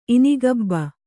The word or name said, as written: ಇನಿಗಬ್ಬ